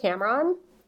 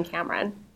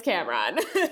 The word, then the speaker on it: Cameron: Either one is okay. I pronounce it Cameron, but yeah, I'm okay with you using Cameron.